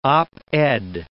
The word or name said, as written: op-ed